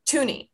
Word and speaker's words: Thuney: How do you pronounce it? – Thuney. – Yeah. Okay. – Oh, really? – Like the Canadian $2 coin.